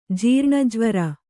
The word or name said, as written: ಜೀರ್ಣಜ್ವರ